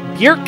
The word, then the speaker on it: Björk: We've been talking about Björk, not Bjork. Björk rhymes with jerk.